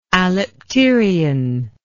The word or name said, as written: alipterion